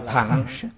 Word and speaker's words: thừa: Chữ thừa này nên đọc khứ thanh, đọc là thẳng. Thế nhưng nay đọc là thẳng thì có người cảm thấy kỳ lạ, đã đọc sai. Vì mọi người đều đọc là thừa, tại sao lại đọc là thẳng? Đọc thừa là nói danh từ, đọc thẳng là nói động từ, tức là chuyên chở.